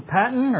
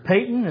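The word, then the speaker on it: Paton: Those words were spoken to John Patton, or Peyton, as some pronounce his name, shortly before he departed for the New Hebrides Islands in 1857.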